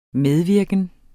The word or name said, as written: medvirken